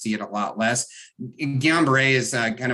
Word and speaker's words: Guillain-Barré: COVID vaccine causes the GBS, Guillain-Barré syndrome. – Yeah. Guillain-Barré.